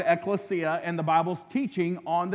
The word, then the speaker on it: ekklesia: Well, we're talking about the biblical word that we get the idea of church from, and that word is a Greek word called ekklesia and you pronounce it just like you see down, down, down there in English, ecclesia.